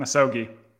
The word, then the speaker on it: misogi: Misogi.